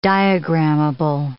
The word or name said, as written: diagrammable